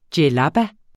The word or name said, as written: djellaba